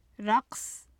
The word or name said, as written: raqs